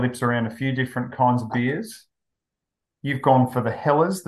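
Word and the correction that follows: Helles: this evening. – I'm told it's pronounced Hells.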